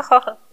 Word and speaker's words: Jojo: sí, sí, sí, la película de Yo Yo le digo Jojo, porque así se escribe.